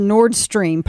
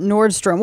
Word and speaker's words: Nord Stream: She has no idea what the Nord Stream pipeline is. No. She called it Nordstrom.